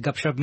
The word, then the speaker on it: Gupshup: Yeah, and Gupshup means chit-chat, by the way.